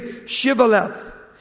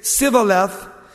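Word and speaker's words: shibboleth: You have to say Shivaleth. And he would say Sivaleth. They couldn't pronounce the CH.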